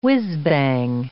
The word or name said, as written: whizbang